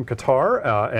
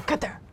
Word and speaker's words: Qatar: And this is a team, I copy you from Qatar, and their project. – You're pronouncing it wrong. – Oh, it's Qatar.